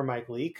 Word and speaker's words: Mike Leake: Duvall has had such a nice career since the Giants traded him away for Mike Leake.